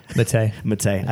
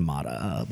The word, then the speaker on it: Matei: How do you pronounce his last name? – Matei. – I thought it was Mata, but he's more like you.